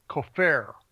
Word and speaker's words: kafar: Again, primitive root, kafar. Kafar, I think the accent is on the second syllable, to cover.